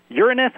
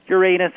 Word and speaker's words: Uranus: Uranus is how most astronomers say it. Uranus is another fine way to say it.